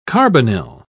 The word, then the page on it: carbonyl: British English